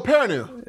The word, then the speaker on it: Perrineau: I'm going to say his name wrong. Harold Perrineau? – Oh, Perrineau. – Yeah.